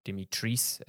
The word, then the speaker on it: Dimitrescu: You get to see Lady Dimitrescu, or Dimitrice as apparently it's pronounced.